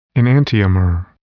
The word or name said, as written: enantiomer